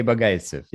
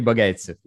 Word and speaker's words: Bogaitsev: Sergey Bogaitsev. Yes. My name is Sergey Bogaitsev.